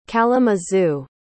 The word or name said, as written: kalamazoo